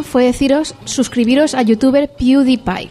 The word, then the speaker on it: PewDiePie: YouTube PewDiePie, ¿cómo se dice? – PewDiePie.